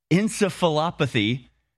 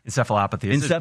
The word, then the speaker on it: encephalopathy: From Vaccine Antidote on X, here is the proof that the DTaP vaccine causes encephalopathy, leading... How do you pronounce it? – Encephalopathy. – Encephalopathy.